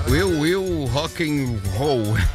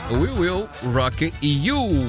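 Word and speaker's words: We Will Rock You: O pessoal sempre, né, perguntava assim, como é que se pronuncia essa música? É, We Will Rock and Roll. A gente falava isso, né? Mas na verdade é We Will Rock and You.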